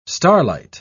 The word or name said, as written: starlight